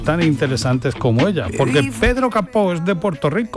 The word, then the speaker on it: Pedro Capó: Es un artista que se llama Pedro Capó, con acento en la O, Pedro Capó.